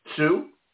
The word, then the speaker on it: Tsu: Sue? Is that good? – Yep, that's right.